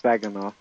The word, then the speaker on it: Saginaw: Is that how you say it? Saginaw? – Yes, Saginaw.